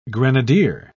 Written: grenadier

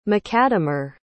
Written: macadamer

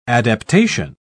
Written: adaptation

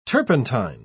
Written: turpentine

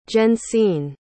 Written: jensine

